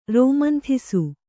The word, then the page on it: ರೋಮಂಥಿಸು: rōmanthisu